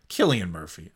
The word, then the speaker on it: Cillian: Yeah, Cillian Murphy.